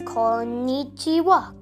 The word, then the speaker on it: konnichiwa: So hello is konnichiwa. So hello is konnichiwa. Say it twice, konnichiwa.